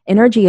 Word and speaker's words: Nrgy: Energy is spelled N-R-G-Y.